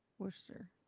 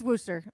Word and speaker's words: Worcester: But it's Worcester. That's how you say it. It looks like Worcester, whatever. But anyway, it's Worcester.